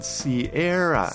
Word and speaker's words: Sierra: Right, and the pronunciation in English is called Sierra.